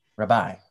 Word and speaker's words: Rabai: So the correct pronunciation of my last name is Rabai. – Rabai. – It's Italian. Mm-hmm. I'm a paisan. So if it was with an Italian accent, Rabai, Rabai, right?